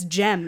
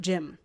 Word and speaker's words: Jem: Just like it's Jem and not Jim.